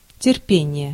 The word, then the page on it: endurance: American English; [ɪnˈdjʊə.rəns]